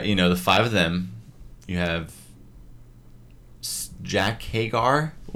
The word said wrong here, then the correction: Hager: He was pronouncing it as Hagar, but then because Jericho had Sammy Hagar on the mind, probably. I don't know, but it's not Hagar. I think it's Jake Hager.